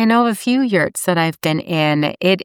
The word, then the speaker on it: yurts: For instance, if I wanted to become the number one real estate agent in North America, specializing in yurts, is that how you pronounce it? – Yes.